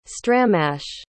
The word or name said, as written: stramash